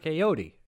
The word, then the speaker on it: Ayoade: it's A-Y-O-A-D-E. Don't say just like Jan. Ayo-di. I was the one who said the name wrong. – Yes, you were. – It's like Ayo-di.